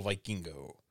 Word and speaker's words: Vikingo: Kenny Omega defeated El Hijo del Vikingo. – Vikingo is how they pronounce it.